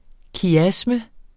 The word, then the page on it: kiasme: kiˈasmə; ˈkjasmə